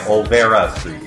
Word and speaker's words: Olvera Street: Am I saying that right? Or is it Alvera? It's like, with an O. – It is with an O, so I'm going to go with Alvera Street. – O and then ends with an A, right? – Yeah, Olvera Street. Yes.